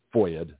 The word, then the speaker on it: FOIA: Gutzkow, who has secured numerous FOIA documents, that's F-O-I-A, they call it a FOIA, which is a pronunciation of Freedom of Information Act, F-O-I-A acronym.